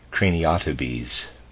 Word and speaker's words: craniotabes: I'm going to pronounce that, I'm afraid, craniotabes and ordinary rickets.